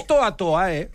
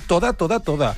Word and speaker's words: toda: Que no és toa toa, perquè ell pronuncia bé, diu toda. Sí. I la gent diu toa.